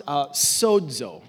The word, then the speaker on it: sozo: It's S-O-Z-O, next slide, but it's pronounced sozo. It's like there's a D in there.